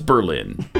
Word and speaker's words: Berlin: Where is Berlin? – Germany. – Boom. Indeed it is. It's also in Connecticut. Although they don't pronounce it that way. It's Berlin in Connecticut.